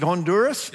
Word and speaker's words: Honduras: Is it Honduras? – Yes.